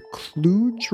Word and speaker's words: Cluj: I've just been on a road trip from Berlin to Romania via Prague and Budapest all the way to Cluj, Romania. – Uh-huh. – I'm probably saying that wrong. – No, you are saying it correctly.